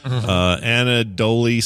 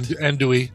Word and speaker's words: andouille: Anadoli sauce. Am I saying that right? – Andouille. – Andouille. – Like Andouille sausage, like it's in your jambalaya.